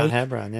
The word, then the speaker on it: Mount Hebron: They took down Mount Hebron, I believe I'm saying that right. – Mount Hebron, yeah.